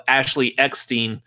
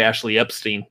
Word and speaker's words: Eckstein: I've seen content lately now of Ashley Eckstein. I even get her name, pronounce her name properly now. I used to say Ashley Epstein, but...